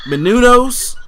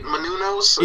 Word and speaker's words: Menounos: Menounos, I believe it's pronounced. Okay, that sounds about right. Menounos.